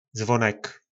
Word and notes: zvonek: po našimu

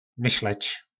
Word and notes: Myslec: po nasimu

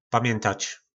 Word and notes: pamjyntac: po nasimu

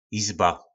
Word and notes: jizba: po nasimu

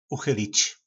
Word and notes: uchylic: po nasimu

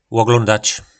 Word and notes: loglondac: po nasimu

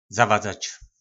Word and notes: zavadzac: po našimu

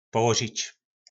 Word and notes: polozic: po nasimu

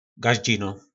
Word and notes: gazdzino: po nasimu

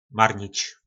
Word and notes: marnic: po nasimu

